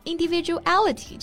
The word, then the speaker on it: individuality: 那我们知道 individual 它表示个人个体，那 individuality 就表示个人特色或者说个性。 – 对，那读音上呢也要注意啊，重音呢是放在梅花音 a 上面 individuality。